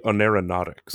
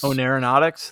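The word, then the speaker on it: oneironautics: I'm going to mispronounce this too. – It's going to be Oneronautics. – Oneronautics, thank you.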